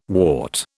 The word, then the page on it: wart: British English